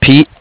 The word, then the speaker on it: Pete: Pete.